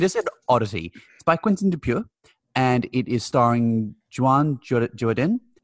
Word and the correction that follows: Jean Dujardin: I'm sorry if I mispronounced Jean Dujardin.